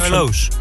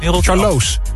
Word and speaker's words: Charlois: Tussen Charlois, zeg ik het goed? Charlois. Ik vind dat altijd moeilijk. Is het Charlois? – Charlois. – Charlois. – Rotterdam-Charlois.